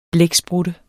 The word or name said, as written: blæksprutte